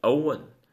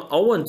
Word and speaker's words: own: 注意我的读音，上面是读的是 own，有个鼻音 n，而下面是 owe，就是什么呢，一个没有带鼻音的词。那么 own 这个词记住了，它就有两个词性，一个是动词，就表示什么呢，拥有。